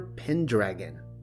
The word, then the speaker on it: Pendragon: He went by the name of Uther Pendragon, FYI. Mm hmm. It is pronounced pen, but then you have to follow the syllables through to dragon.